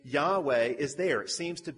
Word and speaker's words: Yahweh: It used to be pronounced Jehovah. Nobody really knows how to pronounce it, but in Hebrew, it's those four letters, Y, H, W, and H. And so scholars today will pronounce it Yahweh. That's probably about the best that we can do.